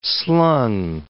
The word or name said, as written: slung